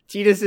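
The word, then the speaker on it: Tidus: Titus is yeah. is the canon pronunciation for it.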